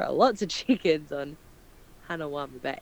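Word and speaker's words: Hanauma: Hanawama.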